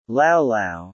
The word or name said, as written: Lau Lau